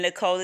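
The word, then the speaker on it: Nikole: And Nicole is spelled N-I-K as in kite, O-L-E. And Brandy is spelled B-R-A-N-D-I-E.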